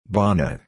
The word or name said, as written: bonnet